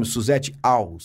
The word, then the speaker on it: Susete Alss: Eu falei certo o seu nome, Suzette Aus. – Suzette Aus.